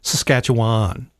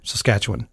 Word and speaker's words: Saskatchewan: Because a lot of people say Saskatchewan. They overpronounce that last syllable. – Why? What? How is it supposed to be said? – Saskatchewan. – Oh, is it? – Yeah. – I say Wan.